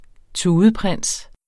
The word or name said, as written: tudeprins